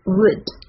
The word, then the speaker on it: would: Would you mind if I asked you a question? 让我们来看一下发音部分吧。 The first one, would. W-O-U-L-D, would. 这里是短音 would. You, Y-O-U, you.